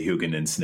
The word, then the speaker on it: Huguenin: One was he got an assist by, how do you say Huguenin's name? Did I say that right? – That sounded pretty good. – Huguenin. Sort of like Favre.